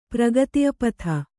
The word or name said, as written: ಪ್ರಗತಿಪಥ